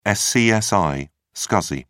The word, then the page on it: scsi: British English